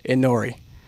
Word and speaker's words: Inori: Inori. I-N-O-R-I.